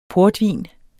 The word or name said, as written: portvin